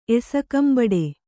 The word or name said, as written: ಎಸಕಂಬಡೆ